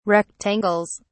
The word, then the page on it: rectangles: British English